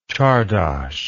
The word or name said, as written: czardas